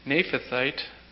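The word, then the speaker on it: Naamathite: So Eliphaz the Temanite, Bildad the Shuhite, and Zophar the Naphathite, that's how you pronounce that, went and did as the Lord told them, and the Lord accepted Job.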